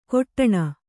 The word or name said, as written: ಕೊಟ್ಟಣ